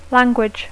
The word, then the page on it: language: English